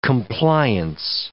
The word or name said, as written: compliance